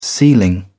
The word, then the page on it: sealing: British English